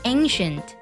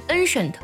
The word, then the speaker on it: ancient: 这个单词的首字母 A 发本身的字母音读作 A，然后后面呢和鼻音相结合就是 an ancient。常见性的发音错误呢，就是很容易把这个单词读成 ancient，成了 n 了，其实它是 an 而不是 n。